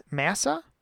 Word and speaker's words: Massah: Massa? M-A-S-S-A-H? – Massa?